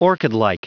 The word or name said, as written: orchidlike